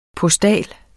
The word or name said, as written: postal